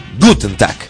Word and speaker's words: Guten Tag: Sí, Guten Tag. Con acento en la gu. Guten Tag.